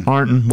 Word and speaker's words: Ironton: Arnton. – Is that how you say it? – Yeah, if you're from there, it's Arnton. – Arnton.